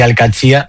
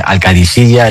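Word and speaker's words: Al-Qadsiah: Bueno, se suele decir Al-Qadsi, pero también hay gente que dice Al-Qadisiyya, es que es difícil, ¿eh? Ah. No es fácil, pero Al-Qadsi es lo más fácil.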